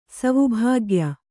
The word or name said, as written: ಸವುಭಾಗ್ಯ